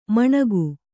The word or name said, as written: ಮಣಗು